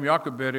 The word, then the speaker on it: Jochebed: Her name, according to Numbers 26:59, is Jochebed. That's spelled J-O-C-H-E-B-E-D, pronounced Jochebed.